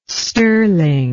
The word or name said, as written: stirling